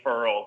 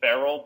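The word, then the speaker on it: Ferrell: but apparently, he told the reporters his name is pronounced Clelin Furl because they were calling him Clelin Farrell, but it's Furl.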